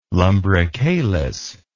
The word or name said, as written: lumbricalis